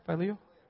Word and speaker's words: Phileo: Am I saying that right? Phileo? Phileo. Oh, phileo fish. Okay, I got it now.